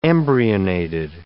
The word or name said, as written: embryonated